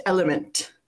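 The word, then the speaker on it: LMNT: Which Glee star was once a member of the early 2000s boy band Element, which is written capital L, capital M, capital N, capital T, but pronounced Element?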